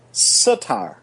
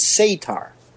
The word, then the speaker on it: Satyr: The UK pronunciation is Satyr, and the US pronunciation is Satyr. And if you want the Greek pronunciation, it's Saturos.